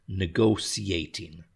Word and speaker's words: negotiating: The UK's, and here's the difficult word to pronounce, negotiating. We have to put the different stresses on the syllables. Negotiating, and then put them together, negotiating. Negotiating, okay? So the UK's negotiating stance, S-T-A-N-C-E, and stance means their position, okay?